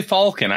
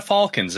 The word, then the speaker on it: falcon: And The first thing that comes up is that in old British English, the correct pronunciation was Falcon.